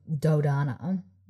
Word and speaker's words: Dodona: Meg responds by channeling the grove of Dodona. Donda. Donda. – No, wait, I said it right the first time.